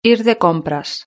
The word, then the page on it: ir de compras: European Spanish